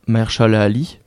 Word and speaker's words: Mahershala Ali: qui est joué par Mahershala Ali.